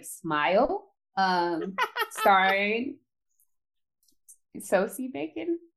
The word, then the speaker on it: Sosie: It's spelled like Josie, but it's Sosie.